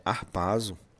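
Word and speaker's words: arpazo (ἁρπάζω): Quer dizer, e, e a pronúncia grega é arpazo, significa levar com violência poderia ser também trazido por rapto, né?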